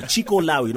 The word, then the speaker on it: Chikolawi: Governor Sakaja there giving us information, by the way, when you finally name my road, please spell it correctly, C-H-I-K-O-L-A-W-I. Chico Lawi, not C.